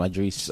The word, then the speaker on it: Idris: It's spelled like Idris Elba, right? – Yeah. – But you say Idris.